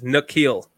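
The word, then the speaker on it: N'Keal: K. Neil. No, Airy? – It's you have it wrong. It's the opposite. It's Nikhil.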